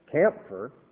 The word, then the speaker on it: camphire: This is campfire, C-A-M-P-H-I-R-E, campfire. And camphor would be a more correct pronunciation, because actually campfire is just an old mode of spelling camphor, C-A-M-P-H-O-R, which of course is our camphor tree, which you're probably very familiar with.